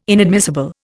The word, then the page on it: inadmissible: Indian